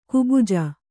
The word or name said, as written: ಕುಬುಜ